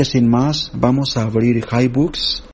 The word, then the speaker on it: HiBooks: Ahora, usted le dice, no, es que a mí me lo pronuncia HiBooks. Bueno, acá le arreglé una configuración en el diccionario del VoiceOver que ya lo pronuncia bien como HiBooks.